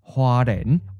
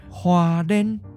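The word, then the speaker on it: Hualien: It's on the east side of the island near the city of Huaren. Or you might also hear some people pronounce it with a different tone. Huaren.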